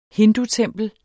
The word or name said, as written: hindutempel